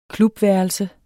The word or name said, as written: klubværelse